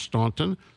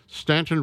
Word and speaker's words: Staunton: And the town is spelled S-T-A-U-N-T-O-N, not pronounced Staunton, Stanton, Virginia.